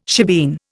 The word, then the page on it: shebeen: Indian